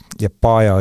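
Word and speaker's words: Liepāja: Liepaja õigupoolest oleks õigem hääldada lätipäraselt, sest lätlased loevad seda viimast A-d pikaks.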